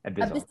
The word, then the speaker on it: Abyssal: Elvish and say it again. – Abyssal. – Abysmal. Abyssal. Thank you.